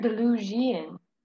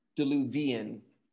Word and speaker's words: diluvian: Are you saying diluvian or delugian? – Diluvian. – Oh. – Yeah, I'll tell you how to spell it. It's spelled A-N-T-E-D-I-L-U-V-I-A-N, antediluvian, of or belonging to the time before the biblical flood, or humorously, ridiculously old-fashioned.